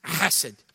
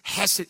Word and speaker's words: chesed: Don't say bless you. It's chesed. That's the way it's pronounced. It's chesed.